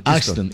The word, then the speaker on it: Easton: Oiga, mucha tractomula, mucho tractocamión, manejando tractomula y saludando a todos los compañeros muleros acá en Ixtons. ¿Se dice Ixtons? Estados Unidos. – Se escribe Easton. – Easton.